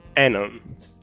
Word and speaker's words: Anon: You're Italian, and how do you pronounce the short form of Anonymous? – Anon. – Yes! Yes! – Yeah. I'm sorry. I just disappointed Meguka, but I pronounced it as Anon. – No, you pronounced it correctly.